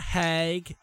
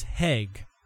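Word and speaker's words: Hägg: Uh, it's not Hag, it's not Haig, it's Heg. That's what the Swedes pronounce it.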